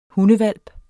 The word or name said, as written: hundehvalp